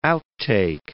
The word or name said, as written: outtake